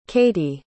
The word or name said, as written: katy